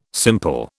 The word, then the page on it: simple: British English